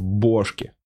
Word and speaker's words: бошки: Футбол, футболка, кислотный отрыв, бошки. Бошки с ударением на О, потому что бошки травы типа? – Да нет, просто...